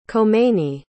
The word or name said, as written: khomeini